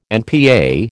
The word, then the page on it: NPA: American English